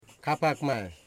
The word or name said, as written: Kapakmay